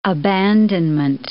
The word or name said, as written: abandonment